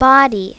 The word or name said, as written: body